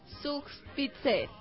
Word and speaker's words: Zugspitze: Zugspitze.